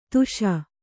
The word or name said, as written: ದಾಶ